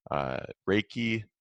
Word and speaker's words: Reiki: Reiki.